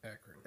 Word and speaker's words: Akron: Akron.